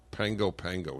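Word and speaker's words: Pago Pago: Pango Pango. Is that how it's pronounced? Yeah. That's it.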